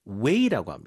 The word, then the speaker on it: whey: W-H-E-Y. 근데 this way, that way. 이쪽이요, 저쪽이요 할 때 W-A-Y랑 발음이 똑같아요. – 발음은 똑같아요. 네. 그렇지만 스펠링은 W-H-E-Y. – Yes. – 이게 way라고 읽고 그다음에 그 유청 말고 걸쭉한 본체 부분은 또 뭐라고 해요? – 아, curd.